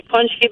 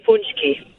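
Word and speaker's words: pączki: It could be punchki, but we also say punchki.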